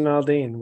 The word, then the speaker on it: Aldean: Jason Aldean.